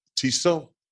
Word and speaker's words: Tissot: The T is silent. Tissot.